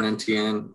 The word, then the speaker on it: Etienne: Travis Etienne, is that the right way to pronounce it? Is out for the year. – Etienne, yeah.